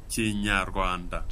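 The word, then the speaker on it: Kinyarwanda: Per, evang- per, evangelizzare in Ruanda bisogna conoscere Cignaruanda. – Cignaruanda.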